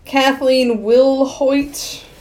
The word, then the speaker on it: Kathleen Wilhoit: Kathleen Wilhoit. That's how you say her name.